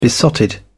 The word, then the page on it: besotted: British English